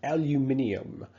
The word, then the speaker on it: aluminium: Aluminum, or of course, how they say it. Across the Atlantic, aluminium. So obviously, this is a common one that people recycle.